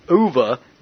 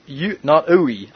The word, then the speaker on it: Uwe: Also, for the record, it's pronounced Uva, not Uwe.